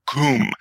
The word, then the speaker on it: cwm: So, Keshi Wirth Ergustr Kum. Why this is hysterical to me is because the word kum, meaning valley, is used in English, and Scrabble Nuts love it because it's spelled C-W-M.